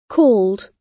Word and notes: called: British English

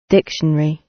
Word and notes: dictionary: British English

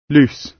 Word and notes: loose: British English

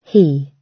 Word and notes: he: British English

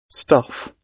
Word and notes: stuff: British English

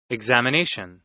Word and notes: examination: American English

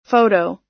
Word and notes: photo: American English

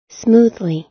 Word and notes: smoothly: American English

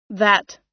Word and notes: that: American English

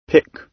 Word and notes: pick: British English

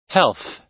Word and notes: health: American English

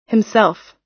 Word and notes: himself: American English

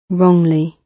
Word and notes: wrongly: British English

